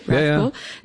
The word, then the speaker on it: Rathcoole: Rathcoole.